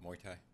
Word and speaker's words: Muay Thai: Muay Thai.